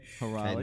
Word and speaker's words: Hyrule: Hyrule.